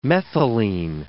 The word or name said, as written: methylene